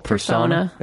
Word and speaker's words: Persona: I read, how do you pronounce the title of your novel? – Persona. – It's Persona. – Yeah. – It's not Persona A.